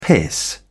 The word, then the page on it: piss: British English